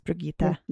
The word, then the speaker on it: Brigitta: Is her name Bridget or Brigitta? – It's Brigitta. I don't know why I say it like that, but this Brigitta. Yeah, it's Brigitta.